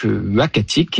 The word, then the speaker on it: Uhackatic: Alors, le nom, ça sera donc Uachatic, et ce nom-là, donc il s'écrit U-H-A-C-K-A-T-I-C, nous a été proposé par le conseil des Inuits d'Ekunitchit, qu'on a contacté justement pour avoir leur avis.